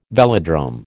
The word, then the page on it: velodrome: American English